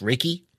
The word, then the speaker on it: Reiki: If I'm pronouncing it right, it's Reiki.